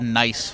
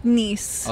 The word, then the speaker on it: Nice: I thought it was called Nice France. Is that a common thing or am I stupid? – That's how it's spelled, but it's Nice. – Okay. – Yeah. In the French language.